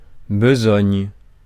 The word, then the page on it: besogne: France; [bə.zɔɲ]